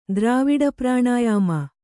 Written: ದ್ರಾವಿಡಪ್ರಾಣಾಯಾಮ

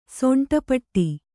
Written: ಸೊಂಟಪಟ್ಟಿ

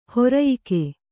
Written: ಹಿರಿಯಕ್ಕ

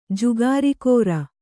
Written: ಜುಗಾರಖೋರ

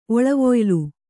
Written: ಒಳವೊಯ್ಲು

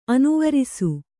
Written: ಅನುವರಿಸು

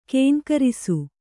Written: ಘೀಂಕರಿಸು

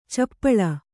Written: ಛಪ್ಪಳೆ

